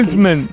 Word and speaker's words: Msemen: Es una palabra complicada, es M-S-E-M-E-N. Msemen, ¿vale? Y se pronuncia.